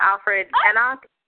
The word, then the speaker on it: Alfred Enoch: Anyone doesn't know who we're talking about, Alfred Enoch. Is that how you say his name? – Sure.